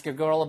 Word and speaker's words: girl: 그랬더니 ask a girl about it, not me. 발음 하나만 주의하세요. 이거 girl 이게 아니고요. girl 한 다음에 뒤에 튀기면서 girl 혀가 이렇게 나왔다가 이렇게 들어오시면서 girl처럼 발음하시면 돼요.